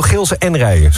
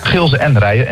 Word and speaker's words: Gilze en Rijen: En het is echt officieel Gilze en Rijen. Zo moeten we het uitspreken. – Ja, Gilze en Rijen.